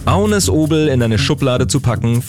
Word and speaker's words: Agnes Obel: Ich spreche von Agnes Obel oder Agnes Obel, wie man sie dann auch auf Deutsch aussprechen kann. Aber auf Dänisch sagt man zu ihrem Namen Agnes Obel.